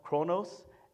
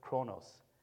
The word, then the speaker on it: chronos: This is not the way the Greeks would say it, but this is how we Americans say it, chronos and kairos.